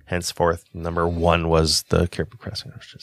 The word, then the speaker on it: Caribou Crossing: So I think number two is the Pendleton, and I think number one is the Caribou Crossing. Did I say that right? – You said that correct, yes.